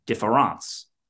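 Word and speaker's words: différance: Now, he takes that word and he takes the E out and he puts an A in, but pronounces it the same way, difference.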